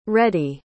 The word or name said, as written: reddy